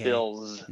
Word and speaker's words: Philz: And Phil's is spelled P-H-I-L-Z. – Oh, I see. Right. Okay. – Phil's.